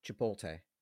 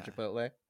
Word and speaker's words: Chipotle: Which word do you think parents mispronounce most? Is it Sudoku or is it Chipoltay? Does every person over 50 years old say Chipoltay? – Yeah. – Or Chipotle? – Yeah.